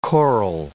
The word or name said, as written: choral